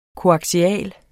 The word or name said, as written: koaksial